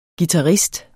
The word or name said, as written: guitarist